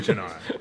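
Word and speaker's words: Cynar: So we got Chinar. – Chinar. – Am I saying that right? – Chinar, yeah.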